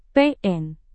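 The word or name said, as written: pol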